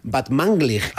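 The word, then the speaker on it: Batmanglij: Este es Rostam Van Vanglig. – Muy bien. Casi bien pronunciado, ¿eh? O sea, tercero de Radio 3 tiene casi. – Van Vanglig. – Batmanglig. – Batmanglig.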